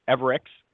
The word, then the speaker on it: Everycs: Well, Christoph Wilfert, and I'm going to try to say the Everycs with an X sound, even though it's spelled, the company is Everycs. It actually is pronounced as an X, Everycs, correct?